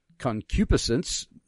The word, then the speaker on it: concupiscence: Concupiscence, see, I said it right that time.